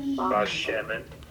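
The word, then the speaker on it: bashemen (בשמן): They didn't put a dagesh in the bait because in the sentence it's going to follow a vowel sound. So the bait would get softened. But just standing alone would be bashemen.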